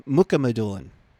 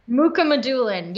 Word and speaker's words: Mukhamadullin: Muhammadulin. Close, Mukhamadullin. – Mukhamadullin.